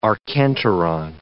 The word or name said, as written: archenteron